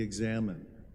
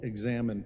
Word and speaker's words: examen: or you can Google examen prayer as well. And it's spelled EX Amen.